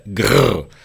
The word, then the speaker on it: Grrr: Vous venez présenter à la scène nationale, à Point Commun plus précisément, sur votre spectacle Grrr. Est-ce que j'ai bien prononcé ? Une expérience, ai-je lu, joyeuse et sauvage, tout en mouvement et qui touche à la nature originelle même de la danse. – Eh bien, oui, vous avez très bien prononcé le grrr.